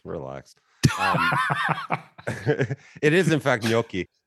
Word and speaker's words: gnocchi: It is not gnocchi. It's gnocchi.